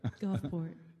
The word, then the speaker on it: Gulfport: The L is silent. Gulfport.